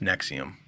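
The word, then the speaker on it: NXIVM: But it's pronounced Nexium.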